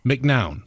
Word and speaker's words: McNown: I always say, because it's M-C-N-O-W-N. McNown. – Yes. So that is how I pronounce it and have pronounced it for my whole life. But I will say, I think the accurate pronunciation from, you know, my ancestry is probably McNown.